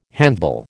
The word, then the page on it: handbell: American English